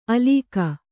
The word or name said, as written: ಆಲುಕ